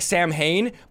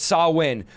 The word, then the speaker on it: Samhain: They say, by the way, the pronunciation of this, it looks like Samhain, but it's Samhain. So this is how Halloween started. Samhain is a pagan religious festival originating from ancient Celtic spiritual tradition. In modern times, Samhain